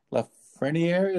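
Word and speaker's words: Lafrenière: This has some big implications because obviously the draft order and, you know, the main draft pick this year being Alexis Lafreniere. Is that my pronunciation? – You got it, yeah.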